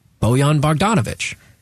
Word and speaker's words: Bojan Bogdanovic: And that is Bojan Bogdanovic.